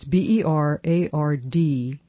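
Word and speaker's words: Berardinelli: It's Bernardinelli. So it's like Bernard without an N, Bernard in Ellie, and then dash S-E-I-P. Bernardinelli sep is a rare disease in which people have no fat at all, and they have a world of problems.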